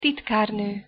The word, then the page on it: titkarno: Hungarian